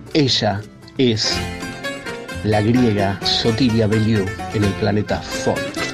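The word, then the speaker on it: Bellou: Su apellido se escribe B larga E doble L O U, Sotiria Beliou o Beliou.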